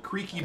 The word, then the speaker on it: Kriek: I have a sour ale from Firestone Walker called Creaky Bones. – Creaky Bones? – And that is creak as in like the Belgian style... the fermented fruit beer, cherry beer. It's spelled K-R-I-E-K.